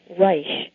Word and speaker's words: Reich: My last name is pronounced Reich. It's a German name, although my father's family didn't come from Germany.